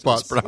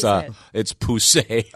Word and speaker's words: poussé: It's not pronounced PUS. – I'm looking at it. Apparently, it's French, and it's poussé.